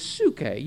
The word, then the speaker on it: psuche: The word soul, the Greek term here, is psuche. You probably remember some of these words. It's spelled P-S-U-C-H-E.